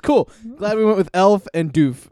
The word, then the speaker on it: elf: It's elf. – Uh-huh. – E-L-F.